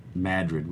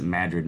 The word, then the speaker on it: Madrid: in Madrid, which is a great place to... – Nice. – Yeah. And they, awesome. – They actually pronounce it Madrid, not Madrid,